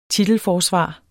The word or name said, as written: titelforsvar